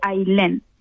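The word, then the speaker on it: Ayllén: ¿Se escribe A-Y-L-E-N? – No, mi nombre es uno de los pocos que conozco que se escriben A y latina doble L-E-N. Se escribe Ailén y se dice Ailén.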